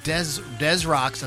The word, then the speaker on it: Des Rocks: But Daniel Rocca, also known as Des Rocks, as he pronounced it, is an American rock musician from Rockville Center.